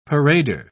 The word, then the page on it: parader: British English